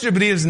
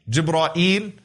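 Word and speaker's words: Jibreel (جبريل): What are some of the ways to pronounce his name? Jibreel, Jibra'il, Jibra'il, Jibra'il, Jibreel. Those are five ways that have been narrated through the various qira'at.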